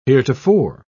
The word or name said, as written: heretofore